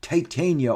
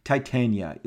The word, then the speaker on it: Titania: Titania, or for your, you know, tomato purposes, Titania. Look, whatever, it's all spelled the same. Titania, it's Titan with an IA, okay?